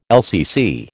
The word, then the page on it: LCC: American English